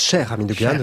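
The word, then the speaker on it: Cheikh Hamidou Kane: Voilà, et Cher Hamidou Kane, que l'on prononce Cher Hamidou Kane, vous avez raison, avec L'aventure ambiguë, sera dans la librairie francophone d'ici deux semaines.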